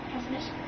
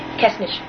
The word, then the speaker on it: Kessenich: Keshnish. – Is it... How do you spell it? – K-E-S-S-E-N-I-C-H. – Keshnish. – Keshnish. – Yeah, they soften the C-H sound in that area.